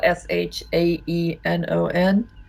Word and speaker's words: Shaenon: My my name is spelled S H A E N O N.